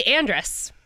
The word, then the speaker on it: Andress: No, it's actually Andress.